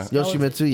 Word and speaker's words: Yoshimitsu: Yeah, I was that Yoshimitsu guy. I think I'm saying it right. – Yeah. – Yoshimitsu, yeah.